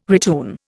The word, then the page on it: retune: Indian